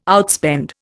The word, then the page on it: outspend: Indian